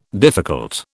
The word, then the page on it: difficult: British English